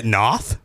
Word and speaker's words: Noth: I don't understand the big deal about this Chris North guy. – Isn't it Noth? It says Noth right here. You keep calling him North.